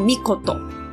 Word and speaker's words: 命: 好，刚刚他爸爸的名字叫做天忍岁尔命，然后儿子呢叫做藩能尔尔义命，他后面都有一个命字，所以呢这边我想要稍微岔出来补充一下，命在日文里面有很多读音，那这个加在名字后面的，它念作 mikoto。